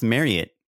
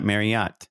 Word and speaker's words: Marriott: And did you know that it's pronounced Marriott? Not Marriott. It's been a really hard adjustment, but in case you've ever wondered, I've been pronouncing it the wrong way my entire life.